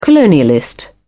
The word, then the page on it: colonialist: British English